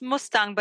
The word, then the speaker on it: Mustang: It's called, it's pronounced Mustang, but it's spelled like the car, Mustang.